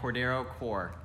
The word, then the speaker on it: Cordero Core: This week we have Cordero Core.